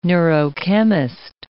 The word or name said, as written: neurochemist